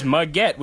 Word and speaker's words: Muggett: His name is Muggett, which rhymes with baguette.